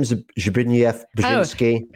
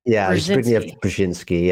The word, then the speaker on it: Zbigniew Brzezinski: Zbigniew Brzezinski. – Zbigniew Brzezinski.